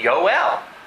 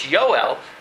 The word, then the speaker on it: Joel: Their son is J-O-E-L. How do we pronounce his name? Yoel. You know, it's not Joel, it's Yoel, because in German you still use the J.